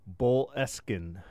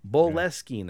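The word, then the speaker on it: Boleskine: I think Lazarus has put the pronunciation down in the chat. Boleskin. – Boleskin House. – Yeah, there you go.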